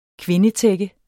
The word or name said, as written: kvindetække